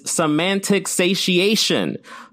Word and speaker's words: semantic satiation: The correct pronunciation of this word is semantic satiation.